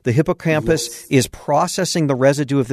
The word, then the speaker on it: hippocampus: You're talking about the hippocampus, and is that how you pronounce it? Hippocampus? – Yes.